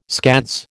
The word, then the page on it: scads: American English